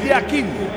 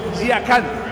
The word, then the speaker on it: Yakin: En el suizo se pronuncia así, Murat Yakin. En turco, Yakan. Cambia la última parte de su apellido.